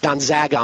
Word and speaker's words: Gonzaga: I already had somebody email me, said I pronounced it wrong. Who cares? It's Gonzaga, I'm told. Okay, fine. Gonzaga.